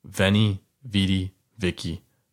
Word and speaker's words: Veni, Vidi, Vici: Someone corrected my pronunciation. I believe there's different forms, but we're going to go with this one from now on. It's going to be Veni, Vidi, Vici.